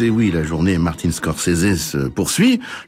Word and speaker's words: Scorsese: Je dis Scorsese parce que j'ai entendu lui-même prononcer son nom ainsi ce matin, donc voilà, quand on aime la musique, on aime aussi la prononciation pas exacte, mais enfin c'est celle qu'il a choisie, c'est quand même son nom à lui.